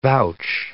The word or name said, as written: vouch